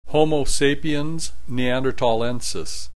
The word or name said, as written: Homo sapiens neanderthalensis